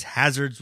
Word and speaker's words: Hazrdz: So if you go to Hazards on like Spotify or Apple, and Hazards is spelled Hazrdz, so it's Hazards without the second A and a Z, Hazards.